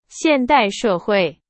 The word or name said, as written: 现代